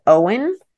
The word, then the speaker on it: Eoin: So this guy, which is funny, his name is Owen, but it's spelled E O I N.